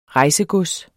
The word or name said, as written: rejsegods